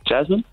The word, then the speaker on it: Jasmin: Jasmin.